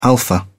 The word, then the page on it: alpha: British English